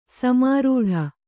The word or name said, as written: ಸಮಾರೂಢ